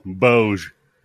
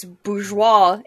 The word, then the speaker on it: bourgeois: Bouge. Bouge 21. – Okay, well, that's not how it's fucking pronounced. It's a French word. It's bourgeois, and it's not rich people.